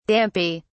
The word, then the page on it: dampy: British English